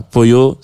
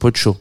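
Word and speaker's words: pollo: le double L se prononce tch voilà, Poyo, c'est pas euh, c'est Pocho.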